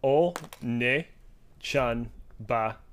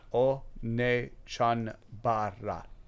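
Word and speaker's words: Onechanbara: So I'm just to break it down by syllable. Onoe Chanbara. – Onoe Chanbara. – Yeah, that's pretty close.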